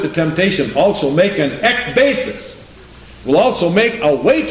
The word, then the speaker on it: ekbasis: I looked up that word end in the concordance, the Greek, and it says number 1545, ekbasis is the Greek word, if I'm pronouncing it right, E-K, and then B-A-S-I-S, ekbasis.